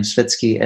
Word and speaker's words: Svetski: It's Aleks with a K-S, so A-L-E-K-S. Then my surname, Svetski, S-V-E-T-S-K-I.